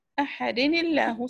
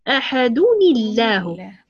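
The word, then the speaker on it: أحدٌ الله: ننطق نون ساكنة في الأخير. – أحدني ننطقها، نلفظها ولكن لا تكتب.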